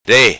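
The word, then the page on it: Re: patua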